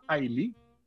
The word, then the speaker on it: Islay: Entonces, hay, hay una isla que se llama Ailey, que se escribe Islay, en Escocia, donde hay siete destilerías.